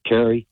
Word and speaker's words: Terry: This is my name is spelled with a T, Carrie.